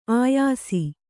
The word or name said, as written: ಆಯಾಸಿ